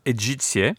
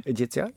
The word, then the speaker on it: Egizia: So this is the Museo dell'Antichità Egizia. – Egizia, yeah. And it was about, I'm impressed with the pronunciation, I'm not going to try it, the Egyptian Museum.